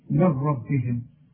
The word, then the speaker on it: من ربهم: انه الحق، او احنا لسه واخدين الحكم ده من ربهم، نون سكنة بعدها راء ادغام كامل بدون غنة، هنقول من ربنا. وعلشان بسهل لك انا الموضوع بقى بقولنا الغي النون من النطق. ولو اقولنا اكتبها زي ما بتسمعها هتكتب لي مين وبعدها ره على طول، يبقى مفيش مشاكل هنا.